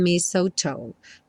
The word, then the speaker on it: mistletoe: And mistletoe. Mistletoe. Tá? Não se confunda com esse T-L-E aí.